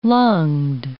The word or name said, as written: lunged